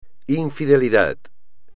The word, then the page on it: infidelidad: espagnol